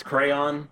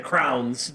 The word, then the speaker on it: crayon: I just have to make fun of the way that you pronounce crayon. All right, crayons. What did I say? – You said crowns.